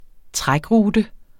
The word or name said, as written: trækrute